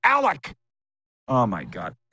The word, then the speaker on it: Alek: So we had somebody in the Blue Jays office watching the show yesterday, and they wrote in and they're mad that I said Alex Manoah. I said both Alek and Alex, but you're right. So I'll put it on me. I apologize. I said your pitcher's name wrong.